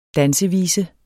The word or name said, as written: dansevise